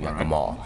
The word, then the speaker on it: Yakima: Heathrow to Dallas-Fort Worth, Dallas-Fort Worth to Seattle, Seattle to Yakima, which I've been told is that's the pronunciation, Yakima.